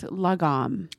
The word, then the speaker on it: lagom: But when you find something that is just right, it's pronounced lagom. – Lagom. – Yeah.